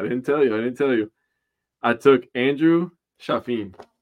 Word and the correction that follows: Chafin: Chaffin? Am I saying his name wrong? Chafin.